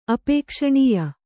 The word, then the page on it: ಅಪೇಕ್ಷಣೀಯ: apēkṣaṇīya